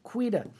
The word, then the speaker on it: Quida: Thomas Quida, if you want to know how to say his name, say quitter like an Australian. Quida.